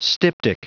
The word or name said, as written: styptic